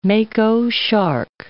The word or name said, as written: mako shark